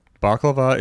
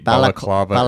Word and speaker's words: balaclava: Baklava is the Greek pastry. No, balaclava is what you pull over your face.